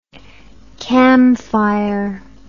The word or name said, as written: camphire